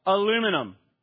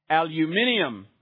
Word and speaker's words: aluminium: And so it's like we say the word in America, aluminum. And how do they say it in the UK? Aluminium, right? And of course, we say it right, and they say, no, we say it right. And there's this debate, right? But it's aluminum and aluminium. So you hear the extra syllable added there, don't you?